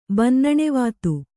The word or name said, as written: ಬನ್ನಣೆವಾತು